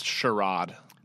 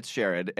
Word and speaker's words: Sherrod: It's pronounced Sherrod. – All right. It's Sherrod, and it passed both the House and the Senate.